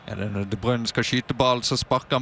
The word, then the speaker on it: De Bruyne: De Bruyne,